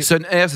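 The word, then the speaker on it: Sun'R: Sun Air, ça s'écrit Sun, apostrophe R, c'est ça R, la lettre R. la lettre. Pas, le, pas le mot ailleurs.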